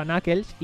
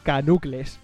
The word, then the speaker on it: Knuckles: Para mí Knuckles vino muchísimos años después, cuando empecé a escuchar a gente hablar de él y lo pronunciaba Knuckles, y yo era, hostia, qué raro lo pronuncian, ¿no? Pero luego te pasa y dices, vale, es que en inglés, Knuckles, tal. Y yo precisamente le llamaba así, Sonic y Canucles. Para mí era Canucles, el puto Knuckles.